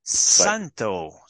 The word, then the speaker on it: Santo: Welcome, Salvo Chiappetta. – Hey, how's it going? It's so to pronounce my name properly, it's Santo Chiappetta. – Santo, Santo.